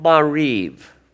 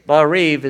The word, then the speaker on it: Maariv: It's called Mariv. Mariv. Mariv is spelled M-A-A-R-I-V. Mariv.